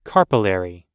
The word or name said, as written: carpellary